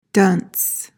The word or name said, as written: dunce